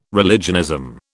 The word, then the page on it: religionism: British English